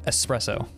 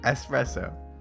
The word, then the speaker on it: espresso: It's 50% coffee, or 50% espresso, 50%, well, and the weird thing about it is actually... – Is it espresso? – It's not espresso. It's not espresso. There's no X, it's just espresso. – Espresso.